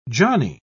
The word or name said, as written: johnny